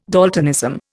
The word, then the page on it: daltonism: Indian